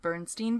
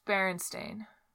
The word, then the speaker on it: Berenstain: Number 24 is the Bernstein Bears. This one confuses me. I never know how to say it. – It's Berenstain. – That's, like, the real way.